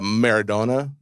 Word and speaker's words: Maradona: And the second most, uh, before this, the most high selling piece of memorabilia was the Diego uh, Maradona. – Is that how mm-hmm. you pronounce it? It is.